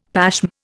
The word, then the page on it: pashm: Indian